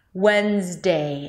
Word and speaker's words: Wednesday: When, when. Wednesday. And make sure to go through the diphthong of the A as in day. Imagine like you have a little Y sound at the end. Wednesday.